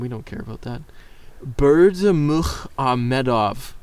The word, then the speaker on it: Berdimuhamedov: So the president of Turkmenistan, I really actually need a moment before I pronounce this, Gurbanguly Berdimuhamedov. – I like it. – Wait, yes. I think it's sexy. Gurbanguly Berhemzadov. – No, that's not right at all.